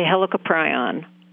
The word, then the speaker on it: Helicoprion: And so the shark, please tell me if I'm saying this wrong, Helicoprion, is that right? – Depends on what part of the world you're from. I think most people say Helicoprion.